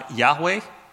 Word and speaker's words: Yahweh: It's probably pronounced something like Yahweh, though you may have grown up hearing the word Jehovah as a substitute,